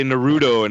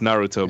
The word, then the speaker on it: Naruto: I have people that say Naruto, and I'm like, dude, it's Naruto, bro. Naruto.